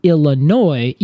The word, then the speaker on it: Illinois: O-I-N-E-N-S-I-S. So it's meaning the caria, which is the nut, from Illinois. – Okay. – Now, Illinois is pronounced Illinois, even though it has like the I-S at the end, right? – Right. – O-I-S. – Is that the French? – It is, yeah.